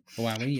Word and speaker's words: Huawei: Huawei, yeah.